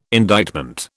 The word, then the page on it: indictment: British English